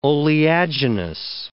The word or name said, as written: oleaginous